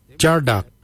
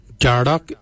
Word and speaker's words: djardag: Jindo is spelled D-J-E-N-D-A-L. Jindo. Then we'll look at jardak. Jardak is grey. Jardak. Jardak is spelled D-J-A-R-D-A-G.